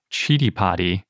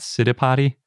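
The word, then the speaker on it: Citipati: By the way, little side note, I found the Sanskrit pronunciation guide for Chidipati, and that seems like it's the correct way to say it and not Siddhipati or Siddhipati, which is especially confusing now because we have Siddhipes and Chidipati, which are both spelled C-I-T-I.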